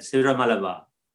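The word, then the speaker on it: Syro-Malabar: Ciro Malabar.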